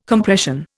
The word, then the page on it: Compression: Indian